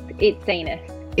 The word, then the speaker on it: Itsines: Yeah, it's Itsines.